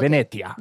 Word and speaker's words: Venetia: Venicia, ¿Como Venisha del Toro, pero Venetia? – Eh, no, se escribe como Venecia, pero con T. – Ah, Venetia. Ya, Venetia.